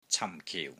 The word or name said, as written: CAM4 KIU4